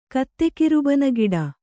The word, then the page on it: ಕತ್ತೆಕಿರುಬನಗಿಡ: kannada